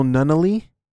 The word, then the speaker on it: Nunnelee: Forgive me, brother, but I believe his name is pronounced Daniel Nunnally. It's Nunnally is spelled N-U-N-N-E-L-E-E.